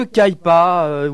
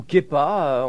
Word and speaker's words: Kaipa: Voilà, c'est le guitariste de Kaipa, oui, ou Kepa, on prononce ça comme on veut. C'est scandinave, mais malheureusement, je n'ai pas fait de suédois.